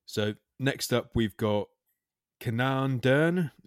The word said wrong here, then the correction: Kaden: Is that, am I pronouncing that right, mate? – Yeah. – Kenan Dern. Kenan Dern. – You have to let us know. – Kaden. Yeah, Kaden.